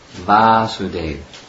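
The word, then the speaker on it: Vāsudeva: Vasudev, the son has a long A, Vasudev.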